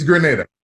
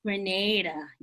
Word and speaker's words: Grenada: Grenada or Granada? Like, what is it? – It's Grenada. – Grenada. – So Granada is a city in Spain, but the country in the Caribbean is Grenada. – Grenada, you see.